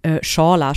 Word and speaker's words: Chawla: Der Krater mit dem Namen Chawla spricht man es, glaube ich, aus. Es steht Chawla, also C-H-A-W-L-A.